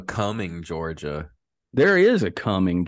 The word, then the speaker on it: Cumming: Um, it spelled like it sounds coming.